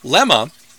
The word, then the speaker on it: Lema: Lema is simply the question, why? It's pronounced with the accent on the first half of the word. It rhymes with the name Emma and often comes out like Lama, because the Hebrew of Psalm 22:1 says Lama.